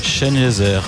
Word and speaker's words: Sengezer: Ça se prononce comme Ebenezer, le Z. – Chenezer, ouais.